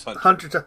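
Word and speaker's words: Hunter × Hunter: It is Hunter times Hunter, actually, but it's just pronounced Hunter Hunter.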